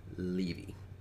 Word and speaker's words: Levy: And also, I'm glad that's finally cleared up as to how to pronounce his last name because, because for a while I've been saying Levy, and then others have been saying Levy, so I'm glad I'm glad it's confirmed. Levy.